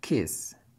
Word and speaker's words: kiss: الإجابة هي kiss, K-I-S-S. – Created with free version for non-commercial use. – متأكد كده لأن في فرق كبير في النطق بين الاتنين. الكلمة اللي فيها حرف ال I دايما بتبقى مكسورة. اسمع كده kiss, kiss.